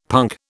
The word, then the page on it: punk: British English